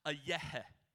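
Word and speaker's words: a yehe (אהיה): Because the Hebrew word for I am, you'll see it on the screen, it's pronounced a yehe. A yehe.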